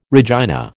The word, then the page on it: Regina: American English